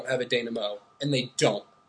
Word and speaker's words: denouement: It's denouement.